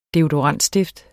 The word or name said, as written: deodorantstift